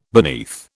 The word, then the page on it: beneath: British English